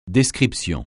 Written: description

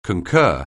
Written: concur

